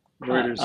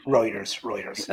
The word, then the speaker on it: Reuters: Is it Routers or Reuters, or how do you pronounce that company? – Reuters. I've always pronounced it Reuters. Yeah. – Reuters.